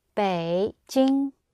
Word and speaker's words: Beijing: Now, the word for Beijing is pronounced Beijing. Bei. Red V for victory. Beijing. Jing. Green sound mouth.